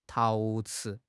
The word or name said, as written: 陶瓷